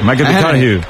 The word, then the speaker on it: McConaughey: Matthew McConaughey. – Sí. – Que hay que ir corrigiendo la pronunciación. McConaughey.